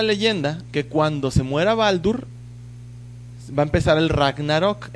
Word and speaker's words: Baldur: Tiene a sus dos hermanos, que uno es Balder, que en, no sé por qué todos en nórdico se pronuncian con U. En inglés es Balder, en español también Balder, en nórdico es Baldur y Thor también es Tur.